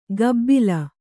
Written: ಕಬ್ಬಿಲ

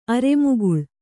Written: ಅರೆಮುಗುಳ್

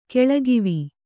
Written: ಗುಳಕವ್ವ

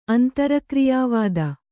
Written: ಅಂತರಕ್ರಿಯಾವಾದ

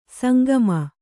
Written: ಸಂಕಮ

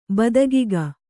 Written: ಬದಗಿಗ

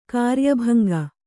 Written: ಕಾರ್ಯಭಂಗ

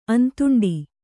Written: ಅಂತುಂಡಿ

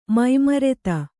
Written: ಮೈಮರೆತ

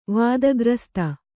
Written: ವಾದಗ್ರಸ್ತ

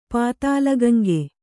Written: ಪಾತಾಲಗಂಗೆ

